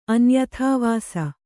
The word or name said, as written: ಅನ್ಯಥಾವಾಸ